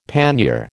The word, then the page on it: pannier: American English